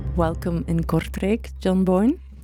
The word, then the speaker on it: Kortrijk: I've been to Belgium a few times in the past, but it's my first time here to Kortrijk. I pronounce that right. – Oh, very good, very good.